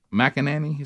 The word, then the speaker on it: McEnany: Kaylee McEnany. – McEnany. Is that how you say her name? – There you go.